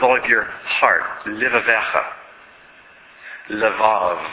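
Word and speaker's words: levav (לבב): In fact, that word levav is spelled in Hebrew, lamed, beit, beit. Sometimes it's just shortened to the word lev for heart. But the full spelling is levav.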